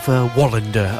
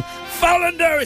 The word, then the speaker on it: Wallander: You know, because Wallander, they pronounce it wrong. – It's Wallander. – You get the nice person on BBC going, and next on BBC we have Wallander. And I'm shouting, Fallander, it's Fallander!